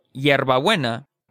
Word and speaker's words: hierbabuena: The thing about la H is that it's never pronounced. It's totally silent. So even if a word begins with an H like I, helado, or hierbabuena, there's no trace of any H sound.